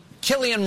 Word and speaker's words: Cillian: Please welcome Cillian Murphy.